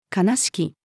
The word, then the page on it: 金敷き: female